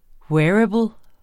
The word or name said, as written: wearable